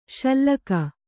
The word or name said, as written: ಶ್ಲಾಘಿ